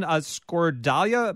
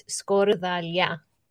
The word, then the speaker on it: skordalia: Skordalia, am I saying that right? – Yeah. Okay. It's okay. Get ready for it. It's skordalia. – Skordalia. – But you can say skordalia in an American accent.